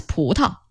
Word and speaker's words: 葡萄: Yes, so is 葡萄。 – 葡萄。 – Yes, so instead of the 1, 2, 3, 4 tone, it's the light tone. – 葡萄、石榴。